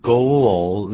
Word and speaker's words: goal: 好了，goal 可变成 goal，念 goal 那个因为有个 l，所以舌尖要抵上来，怎么念的？Goal，goal。